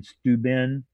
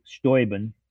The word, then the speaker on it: Steuben: Bortner increased public lands across the state park system, including the Trine State Recreation Area in Steuben. That's the way they pronounce it up there. – Mm-hmm. – Instead of Steuben, named after von Steuben, you know, the Revolutionary War general, sure.